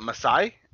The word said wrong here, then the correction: miasma: arms. That's that purple gas. – Miasma. – Miasma.